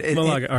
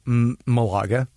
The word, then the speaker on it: Malaga: Malaga, all right. – Well, yeah, in English, Malaga, right? In Spanish, Malaga. – Malaga. Okay, so it's a bit more smooth the way you got to say it, Malaga.